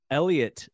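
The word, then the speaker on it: Heliot: Someone who we just learned how to pronounce his name, Elliot Ramos, silent H, not silent T. Yeah. I think I was hard H and silent T-ing his name, but it is Elliot as far as we know now.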